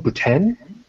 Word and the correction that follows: Buran: Do I have it incorrect? – Buran. – Buran, okay.